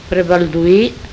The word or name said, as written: Prebaldui